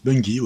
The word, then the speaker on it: Bungie: Alors, comment ça se prononce Bungie Bungie, ouais, tout simplement.